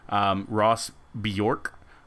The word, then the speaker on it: Bjork: He was at the Texas Tribune, or a panel hosted by the Texas Tribune, along with Ross Bjork, I believe, like the Swedish pop singer. – Singer? – Is how you pronounce that? Yeah, I believe so.